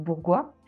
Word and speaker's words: Bourgois: Je m'appelle Noémie Bourgois. Je rigole avec ça parce que 95% des, gens m'appellent Bourgeois, donc euh, voilà.